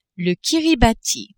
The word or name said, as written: Kiribati